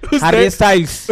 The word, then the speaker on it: Harry Styles: Ese llegó Harries ¿Estiles? Styles, ¿cómo se dice ese puta Harry? – Harry, Harry Styles.